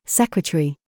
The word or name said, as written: COOK